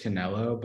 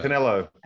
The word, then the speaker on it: Canelo: Am I saying it wrong? Is that why you're laughing? – I think so. I think it's just Canelo. But Canelo.